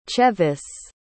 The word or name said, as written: chevis